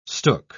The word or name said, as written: stook